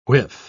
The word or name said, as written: whiff